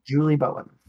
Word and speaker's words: Julie Bowen: It's Julia Bowen. – Julia Bowen. I just meant one more Julie. – Time. – Julie. – To refresh my memory. – Julie Bowen.